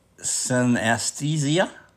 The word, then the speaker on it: synesthesia: Synesthesia? – Yeah.